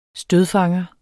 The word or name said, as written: stødfanger